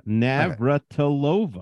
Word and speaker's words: Navratilova: Yeah, Navratilova.